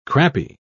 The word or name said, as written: crappy